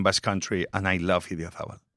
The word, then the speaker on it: Idiazabal: Idiazabal.